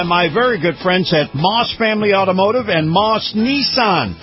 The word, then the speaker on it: Maus: And before you buy elsewhere, check out Moss. That's spelled M-A-U-S.